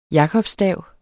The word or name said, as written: jakobsstav